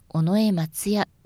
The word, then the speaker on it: 尾上松也: その他、お客様の耳になまって聞こえてしまいそうなのは、尾上松也、中村小太郎、坂東彌十郎です。松也は尾上松也ではなく尾上松也と平らに言います。